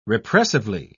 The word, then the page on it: repressively: British English